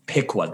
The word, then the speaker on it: Piqua: Piqua.